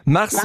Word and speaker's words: Marsens: Marsens, habe ich das richtig ausgesprochen? Das ist Marsens. Wie ist es richtig? – Ja, Marsens. – Marsens. Also kein S am Schluss.